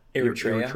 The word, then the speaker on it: Eritrea: How do I pronounce that? – Eritrea. – Eritrea.